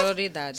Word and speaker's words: sororidade: que é sororidade, né? Eu falei certo? – Sororidade. – Sororidade. Eu falei certo? – Falou. – Ah, ali o meu professor, o Zarinha tá ali, dizendo que eu falei certo.